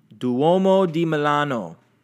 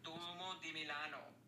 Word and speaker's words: Duomo di Milano: Duomo di Milano. – Duomo di Milano. – Duomo di Milano.